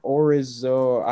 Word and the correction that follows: Odorizzi: It says Odorizzi. – Yeah, sound it out. – That's stinky Odorizzi. Odorizzi.